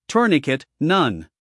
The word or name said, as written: tourniquet